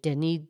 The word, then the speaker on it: Denis: And Denis has an S on the end. So some would say Denis. And then Diderot has a T, Diderot. But the French pronunciation, Denis Diderot.